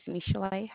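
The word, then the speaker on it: Michel'le: My name is Michelle. How may I help you? – Okay, Ken Paulson Dickinson. What's your name again? – My name is Michelle.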